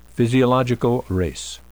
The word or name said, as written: physiological race